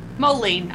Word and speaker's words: Moline: Moline.